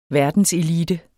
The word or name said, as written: verdenselite